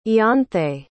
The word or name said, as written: ianthe